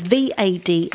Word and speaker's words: Vade: It's VADE. It is pronounced, and I'm glad that Tracy said it this morning, because I would have got it totally wrong. It's Vade is how you present.